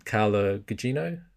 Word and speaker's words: Carla Gugino: and then you had him mirrored by Carla Gugino.